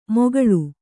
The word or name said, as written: ಮೊಗಳು